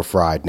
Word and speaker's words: Fried: I've heard it pronounced both ways, Freed and Fried. You know, he's neither free or fried now.